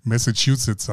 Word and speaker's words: Massachusetts: Ich würde ja gerne mal nach dieser Messe Schuhsitz. Kannst du das aussprechen? – Massachusetts heißt das. – Massachusetts.